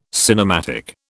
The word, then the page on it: cinematic: British English